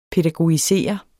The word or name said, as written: pædagogisere